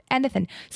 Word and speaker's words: anything: Anything. So it's like almost a schwa. Anything. So it's not pronounced like an E, like we would say anything.